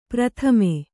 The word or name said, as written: ಪ್ರಥಮೆ